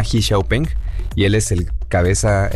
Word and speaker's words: Xiaopeng: De hecho, en China lo pronuncian así, Xiaopeng.